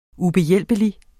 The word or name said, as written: ubehjælpelig